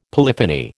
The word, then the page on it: polyphony: American English